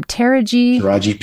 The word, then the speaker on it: Taraji: Tarija, how do you say her name? – Taraji P. Henson.